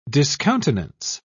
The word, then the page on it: discountenance: British English